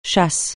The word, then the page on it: chasse: français